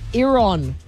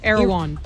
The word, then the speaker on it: Erewhon: Erewhon. – Erewhon. – Erewhon.